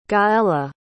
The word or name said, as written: Gaelle